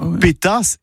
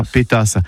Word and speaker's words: pétasse: Si, ça peut je prononce bien. Pétasse et pas pétasse.